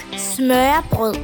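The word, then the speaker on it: smørrebrød: Называется это блюдо «смёреброд», и произнесет, как это произносится по-датски. – По-датски это произносится как «смёрброд».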